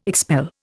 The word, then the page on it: expel: Indian